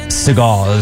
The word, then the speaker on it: Sagal: What do you say her name is? Sagal? Like Steven Sagal? – Sagal is what I'd go with.